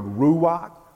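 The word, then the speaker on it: ruach (רוח): The first is in the Old Testament, and it is the Hebrew word ruach. Ruach, R-U-A-H, some people spell it R-U-A-C-H, ruach, Hebrew gets translated to the English word spirit.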